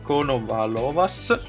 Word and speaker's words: Konovalovas: Conovalo, eh, Konovalovas, scusate, Tobias Ludvinson, Ramon Silkedelman, Mil, eh, Miles Scottson e Attila Walter o Walter.